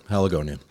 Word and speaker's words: Haligonian: But I mean, you are a lifelong Haligonian. Did I say that right? – That's right. Haligonian.